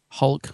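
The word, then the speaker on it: Hulke: The War Games, written by Terence Dix and Malcolm Hulk. Am I saying that right? Hulk. – Hulk.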